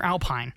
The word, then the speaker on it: Alpine: Is it Alpine or Alpine? – Alpine. – Alpine. Which is the same word. It's just the French pronunciation.